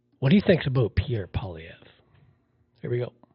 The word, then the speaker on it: Poilievre: Yeah, well, it's French, so it's I don't think he's French, but the name's French. Pierre Poilievre. – Poilievre. – Sort of a weird R, silent R.